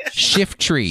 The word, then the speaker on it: Shiftry: It's two syllables, not three. Shiftry. – Shifter.